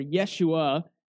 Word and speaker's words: Yeshua: Now, Jeshua, or Yeshua, as it would have been pronounced, it's an alternative spelling of Joshua, or Yahshua.